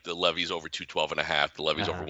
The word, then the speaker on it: Levis: For the record, I believe it's pronounced Levis. That's what I keep Levis. – Hearing. Will Levis.